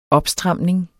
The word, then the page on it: opstramning: -ˌsdʁɑmˀneŋ